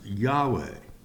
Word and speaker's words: Yahweh: Pronounced in our language, Jehovah, or in the Hebrew, it's pronounced Yahweh.